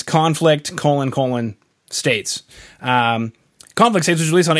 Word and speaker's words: Conflict States: Did I say that right? Is it Conflict States, or do I have to take a pause because of the colons? – Oh, let's go. No, you got it just right.